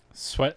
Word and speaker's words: Swett: I really hope I'm saying his name right. S-W-E-T-T. – Sweat? – Yeah. – That sounds right to me. – It's not sweet.